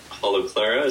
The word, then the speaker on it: Holoclara: Really excited to hear about Holoclara. Is that how you say it? – That's right.